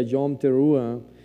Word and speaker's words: Yom Teruah: Ah, hace 10 días ah, estábamos, hablando, ¿verdad?, de la celebración de las fiestas de las, de las trompetas, que se pronuncia Yom Teruah,